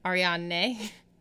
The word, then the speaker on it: Ariadne: And how do you say her name again? – Ariadne.